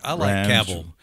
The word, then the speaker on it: Cavill: Cavill. – I like Cavill. – Rhymes with Travil.